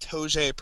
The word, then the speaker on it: Toge: I think it's probably sad that I put 20 hours into a Flash game, but it's made by, I believe it's pronounced Toge Productions. It's T-O-G-E.